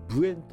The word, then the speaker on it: 無塩: 昔は日の影町などの山間部では生魚が手に入りにくくて、漢字でない塩と書いて無塩と読むんですけども、ない塩、無塩、無塩と呼ばれる、まあ塩がないから当然生の状態のお魚のことですね。